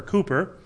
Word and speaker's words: Cowper: And there's another man, his name's William Cowper, but it's spelled Cowper, C-O-W-P-E-R. And he wrote, There is a Fountain Filled with Blood. And I do believe it was William Cowper, or Cooper, who struggled for much of his life with depression.